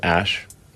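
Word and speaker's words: Æ: The AE is like pronounced Ash.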